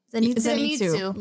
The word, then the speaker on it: Zenitsu: Like, the one that I think of the most is, I'm going to butcher his name, Zenitsu? – Zenitsu.